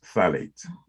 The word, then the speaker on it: phthalate: spells mm-hmm. funny, P H A T H A L A T E, something like that. Right? But it's pronounced phthalate. Mm-hmm.